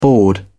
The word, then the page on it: bored: British English